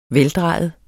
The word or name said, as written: veldrejet